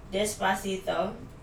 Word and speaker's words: Despacito: Despacito. Am I pronouncing that right in Spanish? – You are, but these are not songs that I was listening to.